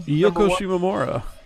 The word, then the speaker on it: Yoko Shimomura: Yoko Shimomura. – That's right. Yoko Shimomura did a... – He pronounced it right, too.